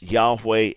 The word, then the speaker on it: Yahweh: Is there any truth to this idea that the letters for the divine name that we would normally pronounce Yahweh and that some through mistaken tradition thought was Jehovah, is there any way that these letters, YHWH, actually mean in Hebrew, behold the hand, behold the nail?